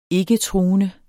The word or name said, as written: ikketruende